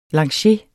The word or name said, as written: lancier